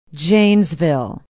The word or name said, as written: Janesville